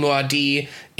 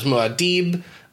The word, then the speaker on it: Muad'Dib: That movie did inform me that I have been pronouncing Muad'Dib incorrectly in my head this entire time. I thought it was Muad'Dib. – That's what I thought it was, too. – They omit the B sound, and so I'm just going to run with that now, I guess.